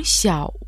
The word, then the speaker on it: 小: «Сяо» в третьем тоне имеет в виду «маленький».